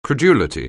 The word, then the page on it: credulity: anglais